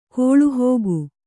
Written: ಗಾಳಿಹಾಕು

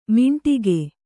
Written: ಮಿಂಟಿಗೆ

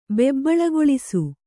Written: ಬೆಬ್ಬಳಗೊಳಿಸು

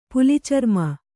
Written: ಪುಲಿಚರ್ಮ

